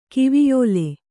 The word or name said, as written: ಕಿವಿಯೋಲೆ